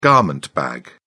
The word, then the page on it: garment bag: British English